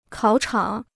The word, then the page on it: 考场: kǎo chǎng